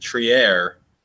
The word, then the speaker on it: Trier: Alonzo Trier, as Tom learned from Jonathan Macri, Trier, which is what Jake's been saying, and I've been questioning this entire time, but apparently that's how his mom pronounces it, so maybe we'll go with that.